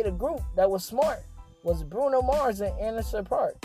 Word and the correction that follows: Anderson Paak: Anderson Paak. I said his name wrong the first time.